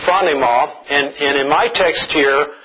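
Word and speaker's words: phronema (φρόνημα): And it is, the pronunciation of the word is phronema, which means nothing, I suppose, but that is how it is pronounced.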